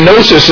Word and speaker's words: gnosis: Just like in the English, by the way, we say knowledge, but the K is silent. They say gnosis in the Greek, and the G is silent.